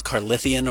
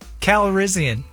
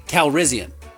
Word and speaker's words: Calrissian: No, I actually didn't know how to say his name. Calrissian, that makes sense. I just didn't know if it was Carlithian or what. – Still wrong. – What is it? – Calrissian. – Calrissian. Lando Calrissian.